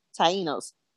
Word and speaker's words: Tainos: It's spelled T-A-I-N-O. How do you say that? – Tainos.